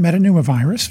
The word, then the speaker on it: metapneumovirus: What can you tell us about, how do you pronounce this, metapneumovirus? – Yeah, human metapneumovirus, very good.